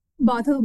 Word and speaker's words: Bothell: Bothell, Washington, yeah.